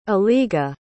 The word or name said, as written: eliga